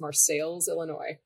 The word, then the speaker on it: Marseilles: It's spelled the same way as Marseille, France, but it's Marseilles, Illinois.